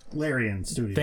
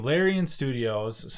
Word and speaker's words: Larian Studios: But Larian Studios. – Thank you. Larian Studios,